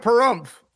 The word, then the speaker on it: Pahrump: I'm getting the hell out of Pahrump.